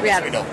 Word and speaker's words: Riedel: I'm here from, is it pronounced Riedel or Riedel? – That's right, Riedel.